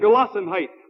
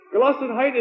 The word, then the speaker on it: Gelassenheit: I agree with him, and I am not German either, but it is a German term. As I understand it, Gelassenheit would be possibly a pronunciation of the term.